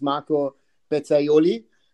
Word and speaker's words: Marco Pezzaiuoli: So, as the way we pronounce it here in Germany, it's Marco Pezzaiuoli.